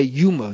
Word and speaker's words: Yoma: you say Yuma. In Israel, I was taught Yoma. I guess Yoma is right. Yoma, probably in Aramaic, is more correct.